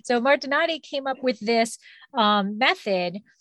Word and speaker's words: Martinotti: Martinotti.